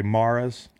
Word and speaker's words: Mara: They've always had gambling in their DNA, whether it was the Rooneys and the what do you say, Maras? – Yep. – Stickler for pronunciation here.